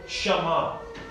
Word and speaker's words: shamah: Then we get into this beautiful word which is called Shama. This Hebrew word which is spelled S-H-A-M-A-H. Shama.